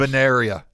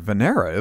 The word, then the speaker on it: Venera: I think it's Venera, isn't it? – Venera? – Yeah. Okay. You got a syllable in there.